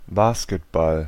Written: basketball

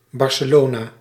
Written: Barcelona

